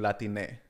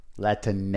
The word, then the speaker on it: Latine: And then now there's people using Latine. – Latine is how you pronounce it.